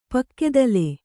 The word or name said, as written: ಪಕ್ಕೆದಲೆ